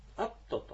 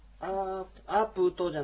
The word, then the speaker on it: アプト: 雨のことはアプトと言います。アプトですね。アープトじゃなくてアプトというこういうアイヌ語のちょっと独特の発音になりますが、アプト、アプトアシナーとかといえば雨が降ったよ、降るよということですね。